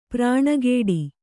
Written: ಪ್ರಾಣಕೊಡು

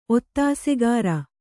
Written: ಒತ್ತಾಸೆಗಾರ

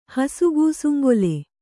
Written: ಹಸುಗೂಸುಂಗೊಲೆ

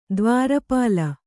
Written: ದ್ವಾರಪಾಲ

